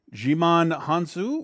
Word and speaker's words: Djimon Hounsou: Oh, I, Djimon Hounsou.